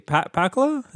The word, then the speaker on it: Pakula: Pakula.